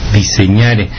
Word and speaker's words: disegnare: y generan el verbo diseñare, o disegnare se escribe, diseñare, que ya tiene el sentido de representar con gráficas lo que uno se está imaginando.